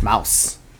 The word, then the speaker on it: Maus: Mouse. – M-A-U-S. – Mouse.